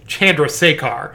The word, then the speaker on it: Chandrasekhar: The most difficult one for me as a very, very white man to pronounce is Chandrasekhar. Yep. But I looked it up, and apparently that is the correct pronunciation.